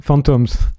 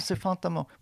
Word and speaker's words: Fantômes: Dans l'esprit de cette collaboration, on va écouter un extrait du collectif Fantasme. Tu prononces ça comme ça, je pense, Fantasme, ouais. – Fantômes. – Fantôme, ah, c'est fantôme. Bon, voilà, en tout cas, c'est un trio que tu formais avec Frédéric Nogré puis Lee Patterson jusqu'en 2020.